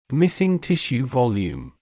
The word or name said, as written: missing tissue volume